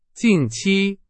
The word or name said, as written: 近期